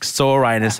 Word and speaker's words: Soranus: It's actually more like Soranus, and I think we really shouldn't go there talking about ancient Greece. Let's say Soranus.